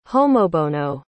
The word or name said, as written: homobono